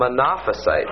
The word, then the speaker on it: monophysites: Well, these monophysitists, or they call them monophysites, is the way you pronounce it.